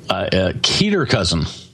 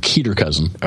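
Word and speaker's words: cater-cousin: Keter cousin. Keter cousin, spelled Cater cousin, but apparently pronounced Keter cousin.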